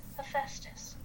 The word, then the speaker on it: Hephaestus: Okay, and then you have Hephaestus, and I'm pretty sure that's how you say it.